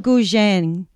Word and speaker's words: Guzheng: Guzheng.